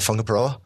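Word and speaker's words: Whangaparaoa: I've moved into a place in, I'm going to get the pronunciation right, Whangaparoa.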